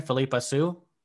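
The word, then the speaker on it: Philippa Soo: Philippa Su? – Yep.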